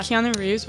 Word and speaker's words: Keanu Reeves: Keanu Reeves. – Is that mm-hmm. his? Yeah. Keanu Reeves.